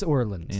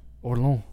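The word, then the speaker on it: Orléans: Where is Orleans? – In France. Yeah. Orlans. Yeah. – It's not Orleans, hey? – No.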